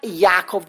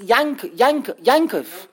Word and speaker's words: Yaakov: And it's not Yaakov, the ayin is not son, it's Yankov. There's an N, the English letter N should be pronounced, ideally, in the letter ayin.